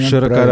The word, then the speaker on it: Широкорад: Правильно я поставил ударение, Александр Борисович? – Правильно, Широкорад. Правильно.